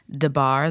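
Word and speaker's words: Des Barres: True Hollywood interview of Pamela DeBar, they say her name that way.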